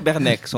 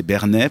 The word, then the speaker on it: Bernex: Les Genevois, ils disent pas c'est Bernet, par exemple, mais il y a Bernex en France. Il y a le X, ça s'écrit pareil, mais c'est en France. Les Français, voilà. Ils disent le X.